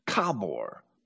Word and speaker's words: Khabor: The biblical river Habor in modern times is spelled K-H-A-B-O-R, Kabor, because that's what it is in the guttural languages of Mesopotamia.